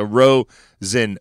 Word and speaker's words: Rosenberg: Rosenberg, and especially because the S is really a Z, so it's even easier. So Rosenberg.